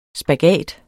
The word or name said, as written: SPAGAT